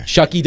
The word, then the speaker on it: Shough: S-H-O-U-G-H. – Very weird. – Yeah, that's why I'm but it's Shuck. – Shuck. – Okay. – Rhymes with duck and buck and luck and you know what else.